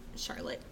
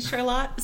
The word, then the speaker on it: Charlotte: Yes, Charlotte, not Charlotte. But. – How do you spell it? – It's spelled exactly like Charlotte, but like the founder's wife, it's named after her, and they pronounced it Charlotte, so that is how it's pronounced.